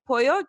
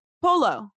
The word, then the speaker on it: pollo: They used to call, like, pollo, chicken, polo. Like, flat polo. – Out. Flat out, polo.